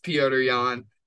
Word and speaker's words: Petr Yan: Number two, as my boy Sean O'Malley would say, Petr Yan, or as it's supposed to be pronounced, Piotr Jan.